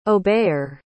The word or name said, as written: obeyer